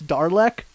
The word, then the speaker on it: Daleks: He pronounced Daleks Darlek, and Gallifrey is Gallifrey.